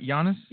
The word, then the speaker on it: Giannis: So the Giannis and – am I saying that right, Giannis? – Yes.